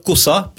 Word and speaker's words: Cosa: Enfin, on devrait dire d'ailleurs Cosa, puisque en latin, le S se prononce comme en français.